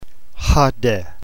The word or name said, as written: hode